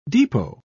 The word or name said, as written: depot